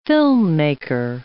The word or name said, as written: filmmaker